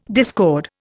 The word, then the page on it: discord: Indian